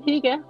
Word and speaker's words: hygge: So am I pronouncing hygge right? – Yeah, you are. It sounds good. Hygge.